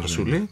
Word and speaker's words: Φασούλη: Επίσης, ένα κομμάτι που τραγουδάει είναι Φέλη Φασούλη, Φασούλη, δεν είμαι σίγουρος που τονίζει το όνομά της. – Φασούλη, νομίζω. Φασούλη, ναι.